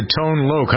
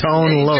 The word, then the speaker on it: Tone Loc: Number four during this week in 1989 belonged to Tone Loc. How'd I do, Jennifer? Is that right? – Very good. Yes. – Tone Loc.